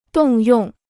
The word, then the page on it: 动用: dòng yòng